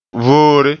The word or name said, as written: voore